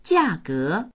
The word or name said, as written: jia4ge2